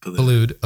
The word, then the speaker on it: Boulud: Daniel Boulud, is it? Or how do you say his name? Boulud. Okay.